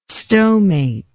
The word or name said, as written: stomate